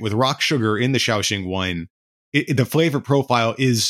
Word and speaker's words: Shaoxing: Is that Shaoxing? Is that how you say it? Yeah, Shaoxing wine.